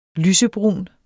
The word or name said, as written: lysebrun